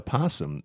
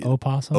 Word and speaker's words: opossum: Or how about the possum? It's technically opossum. – Opossum. – Right. – And I've heard many say it that way. And I'm like, well, you're wrong. That's not how you say it. Technically, it is, I believe.